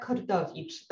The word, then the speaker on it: Kordowicz: So I'm Maria Kordowicz. That's the Polish pronunciation.